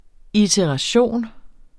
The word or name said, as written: ITERATIONERNE